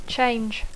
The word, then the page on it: change: English